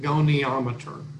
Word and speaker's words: goniometer: How do you pronounce that? – I pronounce it goniometer. – Goniometer. – It's okay. – Possible that I'm mispronouncing it, but my clients, I believe, pronounced it that way.